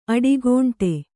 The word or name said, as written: ಅಡಿಗಂಟು